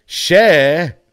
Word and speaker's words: Cher: Cher.